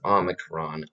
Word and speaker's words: Omicron: I believe I pronounced it Omicron earlier. It's Omicron. O-M-I-C-R-O-N. Omicron.